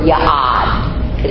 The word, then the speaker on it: ya'ad: There's a Hebrew word, ya'ad. It's not in your handout. It's Y-A-A-D. That's phonetical. That's phonetically spelled.